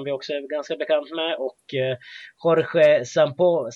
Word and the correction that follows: Sampaoli: Sampaoli, ursäkta uttalet där.